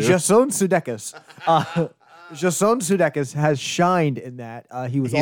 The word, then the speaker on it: Sudeikis: And Jason Sudeikis has really proven himself as I've not heard it pronounced that way. – Sudeikis? – Sudeikis.